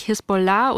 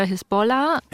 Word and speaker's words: Hisbollah: Da ist immer wieder die Rede von der wie sagt man es eigentlich, Hisbollah oder Hisbollah? – Ja, es hängt immer davon ab, ob du es arabisch aussprichst. Wenn, wenn man es richtig arabisch ausspricht, dann wird es am Ende auf der letzten Silbe betont Hisbollah äh, oder Hisbollah mit E dann auch.